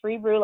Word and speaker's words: Freebrew: Freebrew, like Hebrew, but Freebrew Ilari.